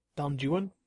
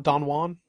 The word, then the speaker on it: Don Juan: Don Juan? – That's how you're supposed to pronounce it, actually. – Not Don Juan? – No, because most of the rhyme structure is based around Juan.